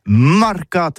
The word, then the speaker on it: marcat: Eh bien, d'ici à tous, le mercat de l'Uni ou le mercat des rebelles, mais en gascogne toulousain, c'est prononcé les marcat avec un A.